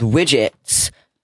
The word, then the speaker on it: WDGTS: But one app called Widgets, and it's spelt weird. One second, let me pull it up. Okay. It's WDGTS.